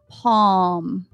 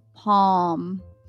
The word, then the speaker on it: palm: One, it could mean that it has a short O sound. Example would be palm. See, it's low, palm, talk And again, your mouth is rounded out a little more like an O. Calm.